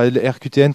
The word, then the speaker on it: RQTN: Donc, ça s'écrit RQTN, comme les lettres, RQTN.